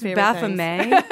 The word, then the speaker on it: Baphomet: Yeah, no, you can wear you can wear a full Baphomet costume on Hollywood Boulevard. And excuse me, it's pronounced Baphomet.